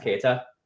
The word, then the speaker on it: Keyra: Sorry, her name is spelled K-E-Y-R-A. That's pronounced Keita.